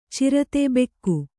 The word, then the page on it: ಛುರಿತ: kannada